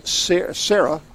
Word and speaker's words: CERRA: Which is Sarah. Mm-hmm. Sarah. Not spelled S-A-R-A-H, it's spelled C-E-R-R-A, right?